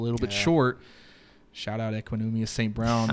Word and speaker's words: Equanimeous St. Brown: I cannot believe you pronounced his name so correctly just then.